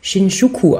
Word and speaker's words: Shinjuku: Oder, ich habe mich belehren lassen, wahrscheinlich spricht man das Shinjuku aus.